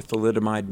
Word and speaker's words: thalidomide: Yeah, thalidomide.